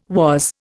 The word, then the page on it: was: Indian